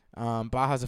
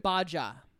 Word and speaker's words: Baja: Baja's a fun word, and also I believe it's pronounced Baja. – Right. – The native tongue, Baja.